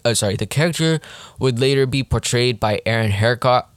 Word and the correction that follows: Eckhart: uh, sorry, Eckhart, sorry, is that how you pronounce his name?